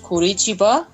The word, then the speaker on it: Curitiba: I learned a lot about your city, Curitiba, right? Did I say it right this time? – Yeah, perfect.